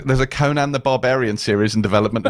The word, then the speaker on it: Conan: it's Conan the Barbarian. it's a two-syllable word.